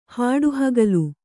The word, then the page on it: ಹಾಡುಹಗಲು: hāḍu hagalu